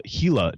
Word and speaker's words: Gila: And the one I wanted to highlight is the Gila National Forest, and it's spelled G-I-L-A, but it's pronounced Gila.